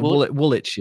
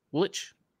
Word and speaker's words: Woolwich: Did I say it wrong? – No, that's Woolwich is it's a whole thing, but Woolwich, yeah. – Woolwich? Okay. Woolwich. – There you go.